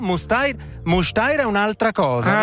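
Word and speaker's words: Müstair: Sono a Disentis e poi io ho sempre detto Disentis Muster nella mia vita, ma mi hanno appena corretto. Qua non si dice Muster, fra un po' vi dico esattamente come si dice. – Mustair.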